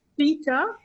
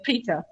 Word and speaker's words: Pretha: Peter, P-R-E-T-H-A, Peter.